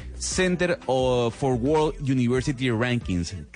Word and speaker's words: Center for World University Rankings: Center for World University Rankings. ¿Cae bien, doctor Pombo, o lo dije mal? No, bien.